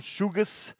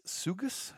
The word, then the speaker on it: Sugus: Shugus, S-U-G-U-S, Shugus, Shugus.